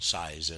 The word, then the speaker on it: Seiz: His name is spelled S-E-I-Z. H-A-R-A-L-D, German, size, S-E-I-Z.